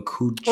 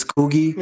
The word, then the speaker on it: Coogie: I don't know if his name is pronounced Coogie or Coochie. – Oh yeah, Coogie. Mm-hmm. Yeah, Coogie. – It's Coogie.